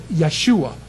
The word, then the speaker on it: Yeshua: Because remember, actually it's pronounced Yehovah, okay, because, you know, they didn't call him Joshua, they said Yeshua. That was Joshua's name.